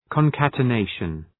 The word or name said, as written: concatenation